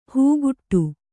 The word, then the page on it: ಹಾಗಡ: kannada; hāgaḍa